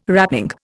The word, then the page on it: wrapping: Indian